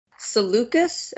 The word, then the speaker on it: Seleucus: These were Cassander, who had Greece and the neighboring countries, Lysimachus, who had Asia Minor, Seleucus, am I saying that right? – Yes, yeah, sure.